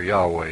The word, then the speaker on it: Yahweh: Jehovah, or Yahweh, if you prefer that pronunciation, speaks verbally to Job.